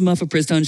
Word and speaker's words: mifepristone: Actually, it's pronounced mifepristone, Shannon.